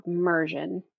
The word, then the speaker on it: Mursion: One of the solutions I'm most excited about is this platform that's called Mersion. It's spelled M-U-R-S-I-O-N.